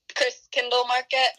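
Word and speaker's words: Christkindlmarket: I'm really not even sure how you say it. – Chris Kindle Market.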